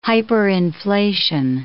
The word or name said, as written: hyperinflation